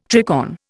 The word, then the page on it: tricorn: Indian